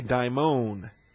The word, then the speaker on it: daimon: And there is a similar term, not here, but elsewhere in the New Testament, called a daimon. D-A-I-M-O-N, with a long O, the omega O, the long O.